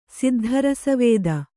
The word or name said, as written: ಸಿದ್ಧರಸವೇದ